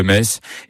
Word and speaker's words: Metz: Le meilleur moyen de mémoriser la prononciation correcte de la ville de Metz est certainement de se rendre au stade Saint-Symphorien, où vous répéterez jusqu'à plus soif.